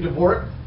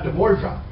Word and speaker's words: Dvorak: I see he pronounces his name Dvorak, not Dvorak.